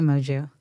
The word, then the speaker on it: Moju: I think technically it's Portuguese. – Oh, Kiano Moju. – I don't know how they say it. – How do you say it? – I say Moju.